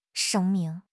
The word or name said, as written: 声明